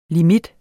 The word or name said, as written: limit